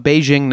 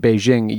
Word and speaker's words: Beijing: The language actually changed so that that J in Beijing used to be pronounced kind of like a hard C or a K.